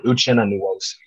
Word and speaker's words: Uchenna Nwosu: I know you said it, but is it Uchenna Nwosu? Is that how you say it? Yes, that's actually perfect. Uchenna Nwosu, Yeah.